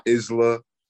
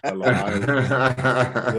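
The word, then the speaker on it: Belle Isle: That's how that's pronounced. It is not Isla. It is not Isla. It's Bella Isle.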